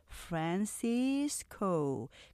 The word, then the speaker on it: Francisco: 첫 번째 지명이죠. 우리가 보통 샌프란시스코 이렇게 지명을 표시해서 이 발음에 익숙한 분들이 분들이 많으시는데 San. 네, 맞아요. 이건 이름절이고요. Fran, Cis, 코 삼음절로 이렇게 발음하시면서 이음절이 강조돼요. Francisco. 그래서 이걸 다 합치면 사음절인데 샌프란시스코 여기가 강조되면서 이렇게 억양과 강약 리듬 만들어주는 거죠.